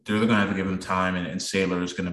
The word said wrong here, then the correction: Salyer: Yeah, Salyer's sorry, it's Salyer. I just realized uh, I've been saying it wrong myself, but yeah you're.